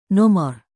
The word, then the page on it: nomor: Indonesian